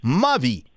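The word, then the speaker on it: Mavì: di Mavi con l'accento sulla I intitolata Nagasaki.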